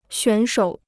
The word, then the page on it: 选手: xuǎn shǒu